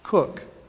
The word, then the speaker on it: Koch: And his real first name was Gerhard, but people just called him Jeff, and his last name, K-O-C-H, Cook, is the way the family pronounced the name.